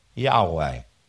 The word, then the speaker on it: Yahweh: So YHWH is Yahweh.